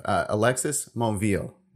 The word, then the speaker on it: Alexis Monville: Now, I messed this up. Alexis Monville. Right? Did I say that right this time? – Yeah, that's absolutely perfect.